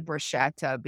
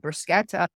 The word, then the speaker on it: bruschetta: But, you know, and there's something called bruschetta, which Americans pronounce it bruschetta because there's a CH, but it's really called bruschetta.